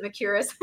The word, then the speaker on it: Mercouris: Social media, Julianne Meyer is, Mercouris now. That's how you pronounce it, Mercouris.